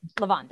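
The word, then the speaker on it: Levain: Not Levion, I always mispronounce it. That bakery with the cookies that are like half baked, that are like giant. – It sounds Levon. Good. – Levon, there we go.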